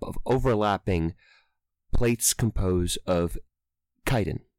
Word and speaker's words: chitin: It's pronounced chitin.